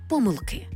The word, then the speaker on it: помилки: До речі, іменники жіночого роду з суфіксом «к», «ечк», «єчк», «очк» у формі множини мають наголос на закінченні. Тобто в однині «помилка» і «помилка» маємо варіантне наголошування, але в множині «помилки».